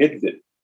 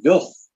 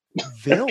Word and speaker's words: Hvidt: The Danish pronunciations, for instance, when you see his name, his first name is quite simple, P-E-T-E-R, you go Peter, but his last name is H-V-I-D-T. So how do you pronounce that guy's name? And before I heard it pronounced correctly, I was calling it Idvid. And I was thinking that I'd never heard that name before, but it doesn't sound right. I know it's not. It's actually pronounced Vilf.